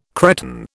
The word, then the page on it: Cretan: British English